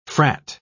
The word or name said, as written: frat